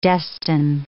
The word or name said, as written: destine